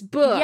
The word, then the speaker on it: Boox: And I will briefly pause here and say, I spent a while puzzling this one out because books, they've decided to spell it B-O-O-X. And I yep. was like, books. Books. Exactly. What does this sound like? What's the word that this sounds like? Books. Oh, it's books.